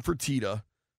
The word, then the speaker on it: Fertitta: Fertitta.